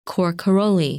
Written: Cor Caroli

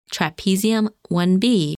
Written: Trapezium 1B

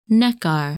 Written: Nekkar

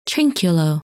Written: Trinculo

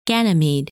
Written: Ganymede

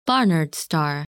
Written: Barnard's Star

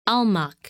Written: Almach

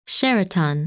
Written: Sheratan

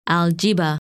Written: Algieba

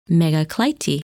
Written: Megaclite

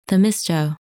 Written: Themisto

